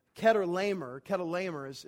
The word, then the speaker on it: Chedorlaomer: These four kings who are all led by this man named, and I want to get it right, Chedorlaomer is how it's pronounced.